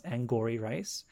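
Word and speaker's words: Angourie Rice: I looked up her name several times. It is pronounced Angouri Rice.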